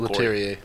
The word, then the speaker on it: Leterrier: Leterrier.